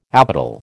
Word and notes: apodal: American English

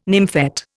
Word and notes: nymphet: Indian

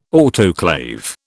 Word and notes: autoclave: British English

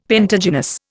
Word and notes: pentagynous: Indian

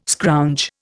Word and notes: scrounge: Indian

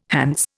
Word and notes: Hants: Indian

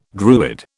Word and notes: Druid: British English

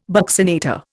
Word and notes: buccinator: Indian

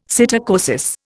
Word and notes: psittacosis: Indian

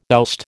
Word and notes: oust: American English

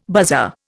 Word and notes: buzzer: Indian

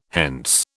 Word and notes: hence: British English

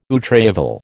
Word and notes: outrival: American English

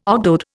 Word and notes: ogdoad: Indian

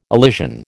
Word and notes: elision: American English

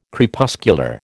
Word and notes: Crepuscular: American English

